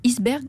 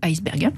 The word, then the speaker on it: iceberg: En passant, les deux prononciations sont admises, iceberg ou iceberg.